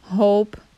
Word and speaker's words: hope: Hop, H-O-P, hope, H-O-P-E. Hop, hope. And they say the E in the end is silent as well. You don't say hope, you know, you say hope. The E just changed the sound of the O.